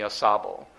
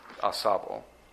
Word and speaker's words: Ausable: The title for this split sermon is Lessons from the Ausable. And that's Lessons from the Ausable. And to spell that, because it is somewhat of a strange spelling, it's A-U space S-A-B-L-E, Ausable.